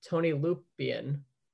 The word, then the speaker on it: Tony Lupien: In 42, before the season started, Fox was informed he would be battling for the first pace job with up-and-comer named Tony Lupien. Is that how to pronounce it? – Yeah.